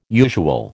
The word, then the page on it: usual: American English